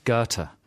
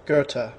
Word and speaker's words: Goethe: G-O-E-T-H-E. – Goethe. – Right, let's have the real pronunciation. – Goethe. – Yeah.